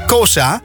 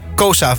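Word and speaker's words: Xhosa: Maar ik kan dat niet, dus ik maak daar Kosa van. Want dat is het wat het dichtstbij komt bij het origineel uitspreken van die taal.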